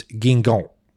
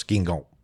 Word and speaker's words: Guingamp: And if you're curious, the team he played for is Guingamp, I believe is how I asked him to pronounce it for us, Guingamp.